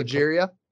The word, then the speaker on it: progeria: I think progeria. – Progeria. Progeria.